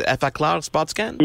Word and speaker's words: Effaclar Spot Scan: Am I pronouncing, is Effaclar Spot Scan? – Yeah, perfectly.